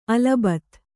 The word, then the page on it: ಅಲ್ ಬತ್: al bat